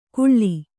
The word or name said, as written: ಕುಳ್ಳಿ